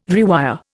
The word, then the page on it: rewire: Indian